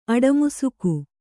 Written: ಅಡಮುಸುಕು

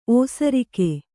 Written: ಓಸರಿಕೆ

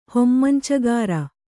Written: ಹೊಮ್ಮಂಚಗಾರ

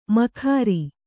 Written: ಮೊಗೇರ

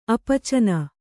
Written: ಅಪಾಚೀನ

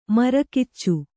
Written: ಮರಕಿಚ್ಚು

